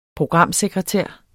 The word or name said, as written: programsekretær